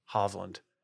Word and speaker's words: Hovland: And Hov, I mean Hove. Victor Hovland. Hovland. – Okay. – It's confusing because I have to call him Hove, but it's Hovland. That's how they all pronounce it.